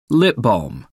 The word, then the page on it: lip balm: American English